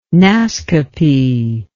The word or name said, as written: Naskapi